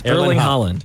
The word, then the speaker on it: Erling Haaland: Okay, Tom Holland DM'd Erling Haaland. – Yes. Erling Haaland. – No, you're making it harder than it is. Erling Haaland.